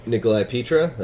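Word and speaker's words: Nicholi Pitra: I'm Nicolai Pitra. That's N-I-C-H-O-L-I-P-I-T-R-A.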